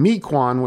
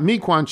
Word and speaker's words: Mequon: From Bill Isaacson, emailer Paul Scazafave mentioned recently that he went to Homestead High School in Mequon, Wisconsin. Tony, to help you out, Mequon should be pronounced Mequon, not Mequon.